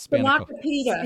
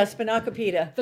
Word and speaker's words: spanakopita: It's not spanakopita. – Yeah, spanakopita. – The girls will love it. – Spanakopita. All right. I'm going to try. I think I'm going to try that. All right. – Spanakopita.